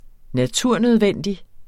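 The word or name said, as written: NATURNØDVENDIG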